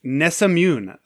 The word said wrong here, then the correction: Nesyamun: a 3,000-year-old ancient Egyptian. – Say that again. – It's Nesyamun.